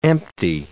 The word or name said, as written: empty